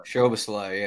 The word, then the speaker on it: Szoboszlai: so Szoboszlai, whatever. – Szoboszlai, yeah. – Szoboszlai, him also, he's midfield only.